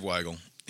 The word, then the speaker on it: Weigel: It's W-E-I-G-E-L. I'm like 99% confident it's Weigel. – Weigel? Yeah. Weigel? – Is he Jewish? – I don't know. Maybe. – Weigel. – Pretty sure it's Dave Weigel.